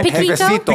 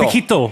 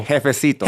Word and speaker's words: jefecito: How do you say that? Pequeño? – Jefecito. – Piquito. – Jefecito. – Jefecito.